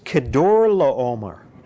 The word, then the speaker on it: Chedorlaomer: And when they rebelled against him, how do you pronounce that? It's Chedorlaomer. Chedorlaomer. That's how you pronounce it. That no, it's Chedorlaomer. I looked it up.